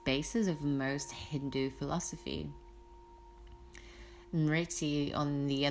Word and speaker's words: Smriti: It's spelled S-M-R-I-T-I, Smriti.